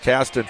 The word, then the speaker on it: Qastin: Spelled Q-A-S-T-I-N, but pronounced Kasten.